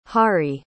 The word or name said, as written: hari